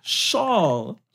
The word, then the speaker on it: Schaal: We love Kristen Schaal. – I like her too. I'm sorry. I'm mispronouncing her name. – Schaal. – Oh. Schaal. Like when it's a little blustery.